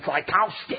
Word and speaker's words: Tchaikovsky: I said, Tchaikovsky.